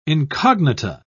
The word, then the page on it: incognita: British English